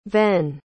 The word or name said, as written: venn